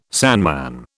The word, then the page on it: sandman: British English